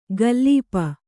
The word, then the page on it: ಗಲ್ಲೀಪ: Kannada